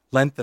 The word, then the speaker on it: length: Length and height. – Well, the first one, length. Length. – Right? Some people don't pronounce the G. They say length instead of length.